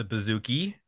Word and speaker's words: bouzouki: He also plays the bouzouki, which is not a bazooka. It's a bouzouki.